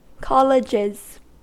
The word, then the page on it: colleges: American English